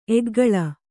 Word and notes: ಏಕಕಾಲ: ēkakāla

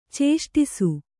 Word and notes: ಚೇಷ್ಟಿಸು: cēṣṭisu

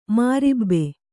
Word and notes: ಮೊರಬು: morabu

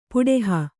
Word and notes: ಪುಡೆಹ: puḍeha

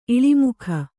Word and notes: ಇಳಿಮೊಗ: iḷimoga

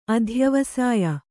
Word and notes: ಅಧ್ಯವಸಾಯ: adhyavasāya

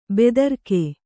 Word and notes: ಭದ್ರಕ: bhadraka

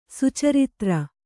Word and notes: ಸುಚರಿತ್ರ: Kannada

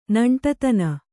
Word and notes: ನೆಂಟತನ: neṇṭatana

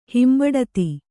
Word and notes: ಹಿಂಬಡತಿ: Kannada

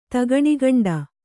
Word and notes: ತಗಣಿಗಂಡ: tagaṇigaṇḍa

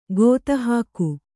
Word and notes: ಗೊತ್ತುಹಾಕು: gottuhāku

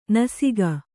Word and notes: ನಸಿಕ: Kannada

